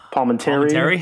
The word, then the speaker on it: Palminteri: I mean, but that year also, too, we also had Chaz Palminteri. Oh, my God, I can never say it right. – Palminteri? Yeah. – Yeah, thank you.